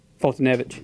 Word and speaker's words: Foltynewicz: Foltynewicz.